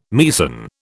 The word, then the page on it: Meissen: British English